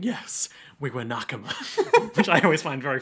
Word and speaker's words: nakama: nakama, I suppose you pronounce nakama.